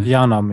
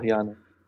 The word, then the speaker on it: Yharnam: Und dann betrittst du halt über einen Grabstein Yharnam, das ist halt die Stadt. Mhm. Spricht man das eigentlich wirklich Yharnam aus? – Ich sag Yharnam, ja. – Ich sag auch Yharnam.